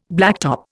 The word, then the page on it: blacktop: Indian